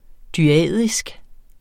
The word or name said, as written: dyadisk